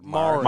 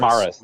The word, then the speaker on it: Maris: How do I pronounce this word? – Maris. – Maris. – Maris. Just Maris. Okay.